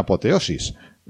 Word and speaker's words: apoteosis: Entonces les dejó mantener el emperador, como ya expliqué en otro programa, es la única persona, el señor Hirohito, que experimentó una hipoteosis, ¿no? Que se le contó de una apoteosis, ¿no? ¿Lo he dicho bien? – Sí, sí, sí.